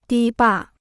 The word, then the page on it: 堤坝: dī bà